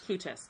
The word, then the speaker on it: Plutus: Plutus.